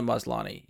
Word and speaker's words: Maslany: is the last name Maslany? Is that how it's... – Tatiana Maslany, yes.